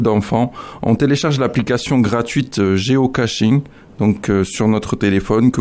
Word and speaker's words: géocaching: Ou plutôt le géocaching, parce qu'il n'y a pas le T, ça s'écrit G-E-O-C-A-C-H-I-N-G.